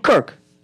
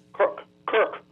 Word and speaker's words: Kirk: The thing with Charlie, how you say it? Cook or something? – Cook. I think Kirk. – Kirk. – Yeah, it's K-I-R-K.